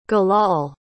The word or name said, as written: gilal